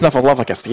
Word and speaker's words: Snuffleupagus: We're talking earlier about Sesame Street characters, and my mom, who's in attendance tonight, Mr. Snugglepuss is who I was talking about, the elephant character. – Snuffleupagus? – Snuffleupagus, yeah.